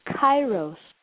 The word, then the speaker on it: Kairos: Right brain is helping us access a whole other kind of time, which is called Kairos. That's spelled K-A-I-R-O-S.